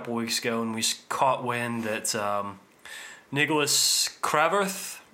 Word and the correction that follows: Kvarforth: I don't know how to pronounce his name. How do you pronounce the Kvarforth? – Kvarforth. – Yeah, sure.